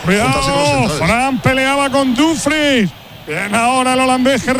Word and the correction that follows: Dumfries: Lo digo bien, Miguelito, Dumfries. – Tengo dudas en esa ¿cómo es exactamente? – Yo creo que es Dumfries.